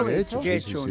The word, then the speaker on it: Getxo: En Guecho, que nunca sé cómo se pronuncia. – ¿Guecho? – Guecho. Yo creo que lo pronunció bien.